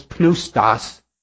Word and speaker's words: pneustos: In English, if you have a pneumatic tire, it's air-filled, you don't pronounce the P. In Greek, you do. So it's pneustos.